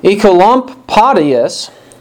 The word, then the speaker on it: Oecolampadius: And one example is what happened in Switzerland, in a town there, where a man named John Ecolampadius, that's spelled O-E-C-O-L-A-M-P-A-D-I-U-S, was part of the Reformation there in Basel, or Basel, in Switzerland.